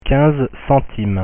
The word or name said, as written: 15 centimes